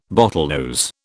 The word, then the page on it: bottlenose: British English